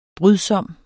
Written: brydsom

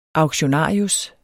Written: auktionarius

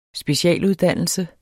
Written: SPECIALUDDANNELSEN

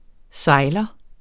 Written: sejler

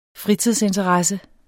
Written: fritidsinteresse

